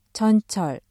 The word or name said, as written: 전철